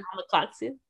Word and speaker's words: Alma Katsu: Would you pronounce her name Alma Katsu? – That's how I've been pronouncing it, yeah. – Alma Katsu? – Alma Katsu, yeah.